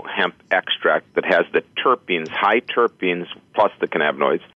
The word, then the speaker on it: terpenes: So, Dr. Ingram, before the break, you were going to get into explaining terpenes. Did I say that right? – Yes, the terpenes, yeah, along with the cannabinoids.